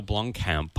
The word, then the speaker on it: Blomkamp: In other Not Real Movie News, Neil Blomkamp. Is that how you say his name? – Yeah, he's the director of District 9 and our favorite movie, Chappie.